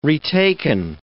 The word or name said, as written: retaken